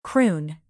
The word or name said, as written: prune